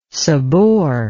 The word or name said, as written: sobor